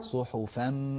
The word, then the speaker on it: صحفا: صحفا، لاحظ فمي، صحفا من الفاء إلى الميم مباشرة. فم صحفا. أعد. صحفا. فم. لا تقول صحفا، هذه نون، أريد أن تنتقل للميم مباشرة.